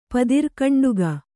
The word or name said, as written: ಪದಿರ್ಕಂಡುಗ